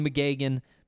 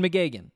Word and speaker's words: McGeoghan: For now, we're going McGagan. For those of you who are curious, it's spelled M C G E O G H A N. We're going McGagan, and we're going to be saying McGagan a lot today because McGagan is a big piece of the Buffs news.